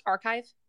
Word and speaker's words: arXiv: Is it pronounced archive? ARXIV. – Yep, good enough.